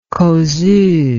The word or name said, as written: causeuse